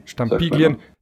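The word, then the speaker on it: Stampiglien: Farbstampilien, spricht man das so aus? – Stampilien.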